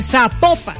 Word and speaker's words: Zapopan: Oiga, nos escriben los oyentes y que sapo, no se pronuncia Zapopan, sino que se pronuncia Zapopan.